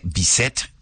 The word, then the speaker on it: Bizet: Se escribe Bizet, B-I-Z-E-T, se pronuncia Bizet, Georges Bizet y sus piezas inmortales.